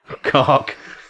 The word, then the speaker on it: Koch: And finally, German favourite, Robin Koch. – Koch. I've been Robin Koch. – So, perfectly pronounced.